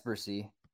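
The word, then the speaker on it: Bresee: I have Brian Breesy. He's my 30th overall player. – Is it Breesy? I've been saying it wrong this whole time. – Yeah, it's Breesy.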